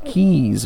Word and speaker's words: cays: The Bahamas is made up of over 700 islands and cays, uh, pronounced keys, of course, for folks out there.